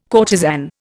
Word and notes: courtesan: Indian

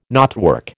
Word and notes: knotwork: American English

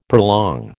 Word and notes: prolong: American English

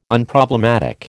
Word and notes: Unproblematic: American English

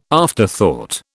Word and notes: afterthought: British English

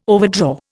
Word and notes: overdraw: Indian

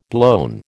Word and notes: blown: American English

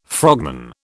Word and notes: frogman: British English

